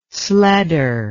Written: sledder

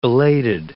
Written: belated

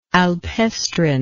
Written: alpestrine

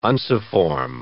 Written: unciform

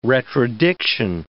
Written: retrodiction

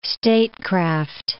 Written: statecraft

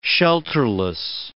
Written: shelterless

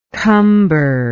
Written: cumberment

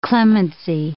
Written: clemency

